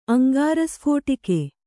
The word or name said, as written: ಅಂಗಾರಸ್ಫೋಟಕ